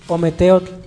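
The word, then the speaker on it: Ometeotl: Y así como separados. – Ome. – ¿Cómo se pronuncia? – Ometeot. – Pues sí. Ome. – Teot. – Sí. Uh-huh. Y cada una es, por ejemplo, Ome es dos.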